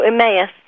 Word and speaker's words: Emmaus: How do you say it? – Oh, Emmaus.